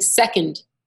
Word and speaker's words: second: So, like if you're saying second, the accent is on the sec, not on the und.